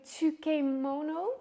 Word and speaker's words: tsukemono: Moet ik het goed uitspreken? Tsukemono? – Dat is op z'n Hollands, dus dat mag.